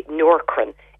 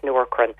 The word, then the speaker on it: Nourkrin: My favourite one for thinning hair is the Noorkrin. It's spelled N-O-U-R-K-R-I-N.